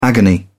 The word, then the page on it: agony: British English